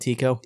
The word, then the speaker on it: Tico: Tico? – Yes.